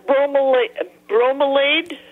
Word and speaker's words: bromeliad: I've got a bromeliad. Okay, that's how you pronounce it, all right.